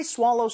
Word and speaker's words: swallow: swallow보다는 swallow. 조금 입모양을 벌려서 발음해 주시면 좋겠습니다. So what if I swallow some water?